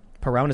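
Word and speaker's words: Perón: Peron is how you say it? – Yeah.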